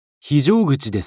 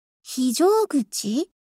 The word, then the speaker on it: 非常口: この漢字は何と読みますか？ – 非常口ですよ。 – 非常？ – 非常口。 – 非常口？ – そうです。